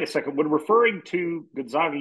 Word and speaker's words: Gonzaga: The pronunciation I'm seeing, by the way, is Gonzaga. Za. So I do think that's the emphasis. – Gonzaga. – Gonzaga. – So it's Gonzaga, but people say Zags.